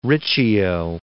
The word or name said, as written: Riccio